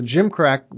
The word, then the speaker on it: gimcrack: It's a G. It's a soft G that starts it off and not a J, so it's spelled G-I-M-C-R-A-C-K, and it just means, so flimsy or poorly made, but deceptively attractive, so.